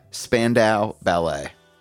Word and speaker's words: Spandau Ballet: Today's song is the breathtakingly sweet True by Spandau Ballet. Is that how I pronounce it? Like, I gotta ask that off the jump. – Yeah. – It's spelled S-P-A-N-D-A-U. Spandau Ballet.